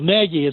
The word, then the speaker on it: Nagy: Well, Nagy is pronounced N-E-E-G-Y.